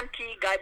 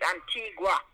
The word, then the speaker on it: Antigua: People say Antigua, but it's not Antigua. It's a G-U-A. But people don't pronounce it properly. It's Antigua, they say. But it's Antigua.